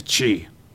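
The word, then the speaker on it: qi: Not spelled like it sounds, no. It's spelled with a Q and an I for some reason, but it's pronounced chi.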